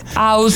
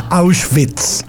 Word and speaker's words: Auschwitz: Sí, acuérdate que la W es como V corta. – Auschwitz. – Se pronuncia como V en alemán. Auschwitz.